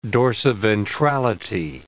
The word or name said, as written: dorsiventrality